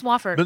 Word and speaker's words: Wofford: Virginia Tech beat Wofford. Am I saying that right? Wofford? – They beat... – You're our South Carolina expert. – I thought they beat... – Yeah, it is Wofford.